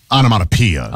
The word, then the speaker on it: onomatopoeia: But anyway, the description was, it was described as starting with explosive onomatopoeia. – Onomatopoeia. – Onomatopoeia. – Yeah. It's spelled poeia, but it's pia.